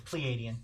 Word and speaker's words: Pleiadian: Is it pronounced Pleiadian or is it Palladian? – Or Palladian. – It's Pleiadian. – Pleiadian.